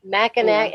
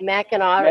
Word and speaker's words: Mackinac: What about Mackinac Island? – Mackinac. Yeah. It's Mackinac.